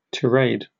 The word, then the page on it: tirade: British English